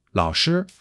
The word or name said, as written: 老师